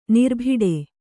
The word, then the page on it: ನರಿಬೇಟೆ: nari bēṇṭe